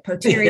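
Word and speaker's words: Potere: I've now learned how to say the name, so I'm just going to keep saying it, Poteri, yeah.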